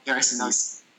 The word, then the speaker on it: Sinise: Yeah, or is that how you say his name? – Gary Sinise.